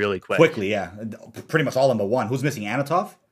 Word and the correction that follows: Antonov: Antonov. – Antonov. I keep saying, bro, hold me to it, man. I keep saying Anatov. It's Antonov.